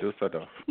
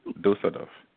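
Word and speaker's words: Düsseldorf: Dusseldorf? Is that right? – Dusseldorf. Dusseldorf. – Dusseldorf, yeah.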